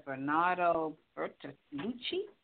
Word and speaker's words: Bernardo Bertolucci: Last Tango in Paris, director Bernardo Bertolucci.